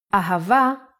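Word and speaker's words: Ahava (אהבה): The word ahava uses it in both ways. When hey appears in the end of the word, it will always sound as ah. Ahava is love in Hebrew.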